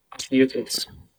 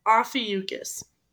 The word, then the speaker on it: Ophiuchus: Ophiuchus. – Ew, I didn't like that. – Ophiuchus. It sounds like he's saying mucus. – Ew, yeah. – Ophiuchus. Ophiuchus.